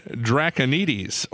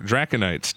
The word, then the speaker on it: Draconites: Yes, John Draconides, or Draconites, depending on how you say it. Erhard Schnepf. – Yeah, I like that. – S-C-H-N-E-P-F.